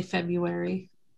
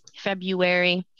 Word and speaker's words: February: I say February. – February. Yeah, who pronounces the R in February? – Somebody.